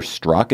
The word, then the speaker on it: Strzok: That is what FBI investigator Peter Strzok, is that how you say his name? – Yeah. Mm-hmm.